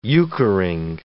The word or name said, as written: euchring